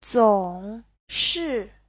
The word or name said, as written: zong3shi4